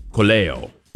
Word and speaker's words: Colello: At 6:30, we'll be talking with Michael Colello. So the L is pronounced in Italian.